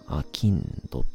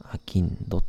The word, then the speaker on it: 商人: パン料理あきんどっていう漢字で商人って書いてあきんどって読むらしいんですけど